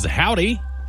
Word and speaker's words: Hovvdy: That's by a couple of Texans who record as Howdy. I talk about it sometimes, but it's spelled H-O-V-V-D-Y if you decide you want to look into them.